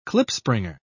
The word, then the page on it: klipspringer: British English